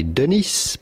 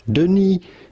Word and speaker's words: Denis: Do not pronounce the S to Denis. It is silent in French. Do not say Denis, but rather Denis Villeneuve.